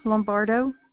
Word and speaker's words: Lombardo: Georgine Lombardo. – Spell it for me, please. – G-E-O-R-G-I-N-E Lombardo. L-O-M-B-A-R-D-O.